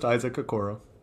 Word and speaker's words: Isaac Okoro: I looked it up. It's just Isaac. I don't know why I heard it pronounced a different way from somebody, but just Isaac Okoro.